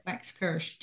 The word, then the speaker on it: Waxkirsh: It's exactly as it's spelled. It sounds as the way it's spelled. So it's Waxkirsh. That's it. – Waxkirsh.